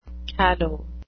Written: callow

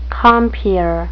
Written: compeer

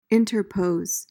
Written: interpose

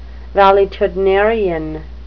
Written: valetudinarian

